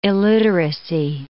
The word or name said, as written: illiteracy